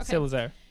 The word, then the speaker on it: Salazar: Salazar. Like Slytherin. – Salazar. – Okay. Salazar. – Like his hair like salad.